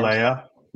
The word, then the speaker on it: Laulea: Sione Lalele. I don't know how to pronounce these Polynesian last names. – Lalea. – Lalea. – L-A-U-L-E-A.